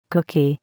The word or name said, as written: cookie